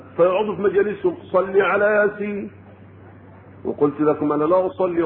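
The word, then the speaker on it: يس: هي لا تنطق إلا كما نطقها أنا، ياسين، يعني أنا أنطق حرف ثم حرف، هم قالوا لا هي ياسين، وطيب يعني قال ده إيه؟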